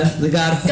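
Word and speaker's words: Hoar: It's H-O-A-R, but they say it's pronounced whore.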